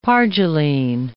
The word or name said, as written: pargyline